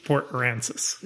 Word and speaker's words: Port Aransas: Pronounced Port Aransas.